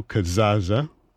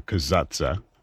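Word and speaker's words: Casazza: And the violinists were Fabio Biondi, Isabella Longo, Enrico Cazzazza, or Cazzazza would be a better way to pronounce that, and also Raffaello Negri with Europa Galant.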